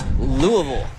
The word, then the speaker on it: Louisville: I'm sorry, I gotta pronounce it. The Geo corrected me. It's Louisville. – Yeah, Louisville for the college one. Louisville around here.